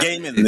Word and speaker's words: Gaiman: Gaiman.